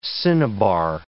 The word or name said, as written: cinnabar